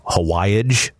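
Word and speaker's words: Hawaij: One that I had to look up, Hawaiage, if I'm saying that correctly, H-A-W-A-I-J, a spice blend. These are the kinds of things worth seeking out. – You nailed the pronunciation, first of all.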